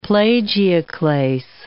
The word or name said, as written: plagioclase